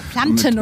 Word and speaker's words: Planten: Du hast es fast richtig gesagt. – Ah, Planten und Blumen. Genau, mit Tee.